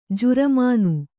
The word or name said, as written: ಜುರ್ಮಾನೆ